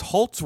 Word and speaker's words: Halse: And it says, Halse rhymes with waltz, not Halsey. No, no, no, no. Halse.